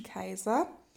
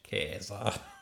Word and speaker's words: Caesar: Das Wort Kaiser kommt übrigens auch von Caesar, das zeitgenössisch wohl mehr wie das Wort Kaiser, so vielleicht ein bisschen wienerisch, Kaiser, ausgesprochen wurde.